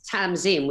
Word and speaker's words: Tamzin: So it's spelt with an S, and it's Tamzin with a Z.